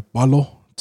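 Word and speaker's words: Paulo: In the front row, you've got Reg and Junes, Junior Palo, I think it's how you pronounce it correctly these days. And I say these days, it's always been that way, but we've always been pronouncing incorrectly.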